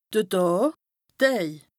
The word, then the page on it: de Dàà, d' Däj (pl): Bas-Rhin; Strasbourg